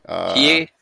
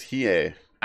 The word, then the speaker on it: Hiei: Oh, Hiei. – It was Hiei.